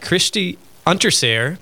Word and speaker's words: Unterseher: We've got Kristie Unterseher.